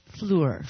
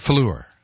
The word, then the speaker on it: Fluor: And I think it would be, say, spell that again for me. – Fleur? – F-L-U-O-R. Fleur. – Fleur. I like that a lot better.